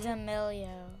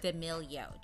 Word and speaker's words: D'Amelio: I really cannot pronounce the last names of Charlie and Dixie. What is it? – D'Amelio. – D'Amelio.